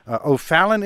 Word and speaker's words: O'Fallon: And our first caller is Sandra, who joins us from O'Fallon, Illinois. Is that how it's pronounced? – Yes, it is.